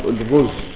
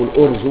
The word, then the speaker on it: الأرز: لو كان الرجل، لو كان الرز أو الأرز، ويقال له الرز ويقال له الأرز، وكلاهما صحيح. أهل المشرق ما يقولون الأرز، يقولون الرز. الرز هكذا ينطقونهم المشارق، الرز، الرز أو الأرز، وكله صحيح.